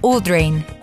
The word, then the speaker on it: Audrain: El siguiente libro es El Instinto y la autora es Ashley Udrain se escribe Audrain, Ashley Audrain.